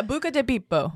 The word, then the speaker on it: Buca di Beppo: That is not how you say it. It's Italian. – Yeah, Buca di Beppo.